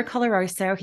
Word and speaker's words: Coloroso: I've got Barbara Coloroso here.